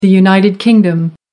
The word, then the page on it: united kingdom: American English